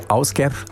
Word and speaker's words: Ásgeir: Ja, spricht sich so. Ásgeir schreibt sich Ásgeir.